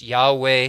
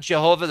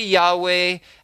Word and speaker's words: Yahweh: God is who he is, and, and his name is perhaps pronounced Yahweh. Uh, it's definitely not Jehovah. There's no J's in Hebrew.